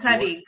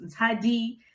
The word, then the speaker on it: Ntšali: It's spelled Lee, but pronounced Ntšali.